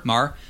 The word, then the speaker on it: Maher: Mar.